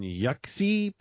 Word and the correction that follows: Jiangxi: province, is encouraging. I know I pronounced those wrong. I'm sorry. Don't come for me. – Yihuang and Jiangxi.